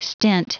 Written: stint

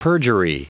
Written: perjury

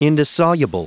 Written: indissoluble

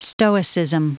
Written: stoicism

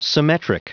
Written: symmetric

